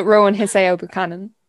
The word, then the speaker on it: Rowan Hisayo Buchanan: This book is Starling Days by Rowan Hisayo Buchanan? – Buchanan, yeah. – Buchanan. – Okay. Do you want to say that in one go so I don't butcher it? – So Rowan Hisayo Buchanan.